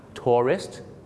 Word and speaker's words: tourist: สองคำนี้มีเส้นบางๆอยู่นะครับในการออกเสียงคือพยางค์สองพยางค์ tourist นักท่องเที่ยวกับ terrorist สามพยางค์ครับ